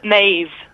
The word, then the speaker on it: Maeve: Maeve. Oh, Maeve. – It rhymes with Dave. – Okay, not Maze, as in the Maze prison.